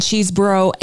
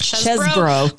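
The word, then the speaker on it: Chesebro: But we've learned in this process is it's actually pronounced Chesbrough. But of course, everyone is still calling it Cheeseborough because it makes more sense.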